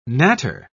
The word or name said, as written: natter